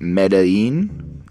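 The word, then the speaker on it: Medellín: I found out that Medellin, is how you say it, is a city and is like the main city in Colombia.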